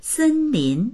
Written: sen1lin2

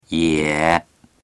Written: ye3

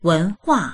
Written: wen2hua4

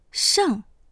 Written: sheng4

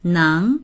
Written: neng2

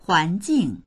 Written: huan2jing4

